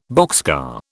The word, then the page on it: boxcar: British English